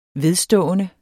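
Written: VEDSTÅENDE

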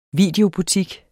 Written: videobutik

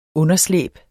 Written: underslæb

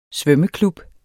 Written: svømmeklub

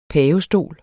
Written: PAVESTOL

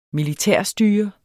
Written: militærstyre

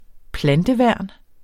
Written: planteværn

